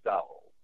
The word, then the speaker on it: Dulles: Resorts International evolved from a CIA front company set up in the early 50s by then CIA director Alan Dulles. D-U-L-L-E-S? How's that pronounced? – Dulles, right.